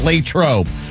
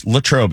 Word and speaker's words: Latrobe: Latrobe is fine, right? Latrobe is how the more pedantic, stickler people of that area pronounce it. But either works for pronunciation purposes.